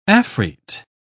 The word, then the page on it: afrite: British English